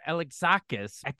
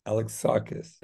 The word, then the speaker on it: Alexakis: But everybody, come check out Art Alexakis, said it right, of Everclear. – Like Alex saw the band Kiss. Alexakis. – There you go. Alex saw Kiss.